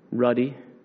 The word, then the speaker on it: ruddy: And it's this little guy, David, said he was, how do you pronounce it, Rudy or Ruddy? Ruddy.